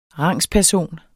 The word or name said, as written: rangsperson